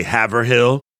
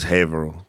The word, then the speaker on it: Haverhill: For people that are listening on the home game, when you come to camp, the sign is going to say Haverhill, but it's Haverhill. Just get used to it.